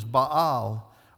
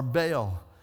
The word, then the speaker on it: Baal: and we pronounce it wrong, the pronunciation is Baal or Baal.